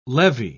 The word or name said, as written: levy